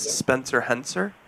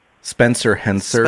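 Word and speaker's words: Spencer Hencer: Well, my name is Spencer Henser. – He may already be in your system. – Henser? – Spencer Henser.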